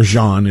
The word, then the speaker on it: Jean: And the brother of Botham Jean, or Jean, it's J-E-A-N, and it's pronounced kind of French,